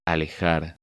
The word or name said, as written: Alejar